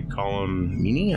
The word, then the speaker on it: Colm Meaney: And of course, Chief O'Brien, you know, he was played by Colm Meaney, I believe that's how you say his name.